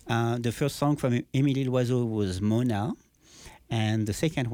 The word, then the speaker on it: Loiseau: From Émilie Loiseau. – Yeah. – So it's not spelled like a bird because there's a Z in the middle and there's no apostrophe, but that sounds like it.